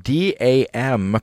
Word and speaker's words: D.A.M.: Before that, it was the hip-hop group D.A.M., pronounced Dam,